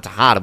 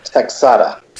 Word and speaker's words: Texada: And Tia Texada Tejada, we'll get her name right one day. Love that woman to bits. – Texada.